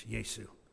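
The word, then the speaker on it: Jesús: And it's still spelled the same, but it's Yesu.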